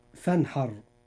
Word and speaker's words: فانحر: الراء في هذه الكلمة مفخمة لأنها ساكنة وما قبلها مفتوح، فتنطق مفخمة هكذا: فانحر.